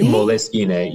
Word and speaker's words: Moleskine: Moleskine, yes. It's an Italian company, Eva. And I am 99% very sure. – I actually have a funny story around moleskine.